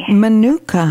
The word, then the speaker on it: Manuka: And now, what is that form, that Mananuka, am I pronouncing it correctly? – Manuka honey.